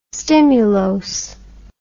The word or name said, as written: stimulose